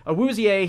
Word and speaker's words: Awuzie: Chidobe Awuzie, sorry, we keep mispronouncing his name, but Awuzie, he's more of a, like, kind of a quiet type.